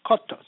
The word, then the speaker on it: skotos: The Greek word for darkness is skotos, pronounced skotos, meaning shadiness, obscurity, or darkness of error.